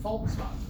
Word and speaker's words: Volkswagen: You spell it with a V, Volkswagen. But you pronounce it Volkswagen.